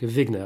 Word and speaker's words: Wegener: Ouais, quand Alfred Wegener, je crois qu'on dit en français, mais c'était un allemand, donc Wegener, allez, je vais m'en tenir à la prononciation normale,